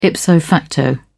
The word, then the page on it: ipso facto: British English